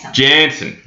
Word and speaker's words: Jansen: Jansen.